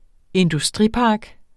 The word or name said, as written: industripark